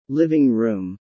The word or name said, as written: living room